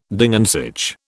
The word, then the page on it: Ding an sich: British English